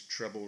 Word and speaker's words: Treble: His name is Treble Reese. Did I say that right? – Treble? Yes, Treble. – Treble. – Like dribble with a T, yes. – Dribble, but treble.